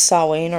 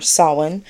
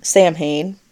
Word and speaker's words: Samhain: The word Samhain itself, which is pronounced Samhain or Samhain, not Samhain.